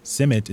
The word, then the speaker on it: CIMMYT: She's the Maize Land Race Improvement Coordinator at the International Maize and Wheat Improvement Center, or CIMMYT, as it's more commonly pronounced. CIMMYT is headquartered in Taxcoco, Mexico, an old rural town about 15 miles northeast of Mexico City.